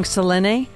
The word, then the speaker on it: Selene Luna: Okay, I'm going to say it wrong. Selene. – Oh, you said it perfect.